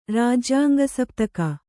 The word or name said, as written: ರಾಜ್ಯಾಂಗಸಪ್ತಕ